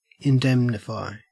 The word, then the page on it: indemnify: Australian English